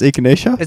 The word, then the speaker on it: echinacea: You can't pronounce echinacea? – Is it echinacea? Yeah. Echinacea.